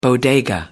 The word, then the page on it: bodega: American English